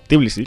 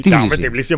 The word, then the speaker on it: Tbilisi: Tbilisi. Tbilisi, hombre, ¿no lo conoces? Tbilisi, tío. – No, pero es que se escribe T-B-I.